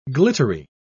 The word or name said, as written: glittery